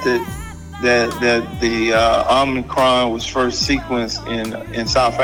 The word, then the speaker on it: Omicron: Am I saying it right, Milt? Omicron? – Yeah, that's right.